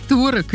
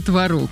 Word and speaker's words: творог: И я сразу почувствовала себя школьницей, которая стоит у доски и не знает, как правильно говорить, творог или творог. – А вот, ну да, уж поясняй, творог или творог, жду. – А ты знаешь, выясняется, что оба варианта в нынешнем правописании, они абсолютно равноценны.